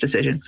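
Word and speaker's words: Dacyczyn: whose name is spelled like it doesn't look like it should be pronounced Decision.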